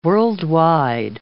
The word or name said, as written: worldwide